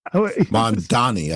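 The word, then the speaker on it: Mamdani: You mentioned the Mondami. Is it Mondami? – Mondani, I think it is.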